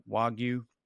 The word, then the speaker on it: Wagyu: Wagyu? – Yes.